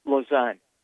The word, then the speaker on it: Lausanne: Lausanne.